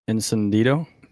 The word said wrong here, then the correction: Escondido: That's Escondido. That's how you say it.